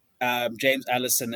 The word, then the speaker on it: James Allison: A lot of people, obviously, James Allison at Mercedes has come back into the technical director role. Remind me if am I saying that right? And yes, you are.